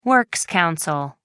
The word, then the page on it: works council: American English